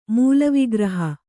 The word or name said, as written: ಮೂಲವಿಗ್ರಹ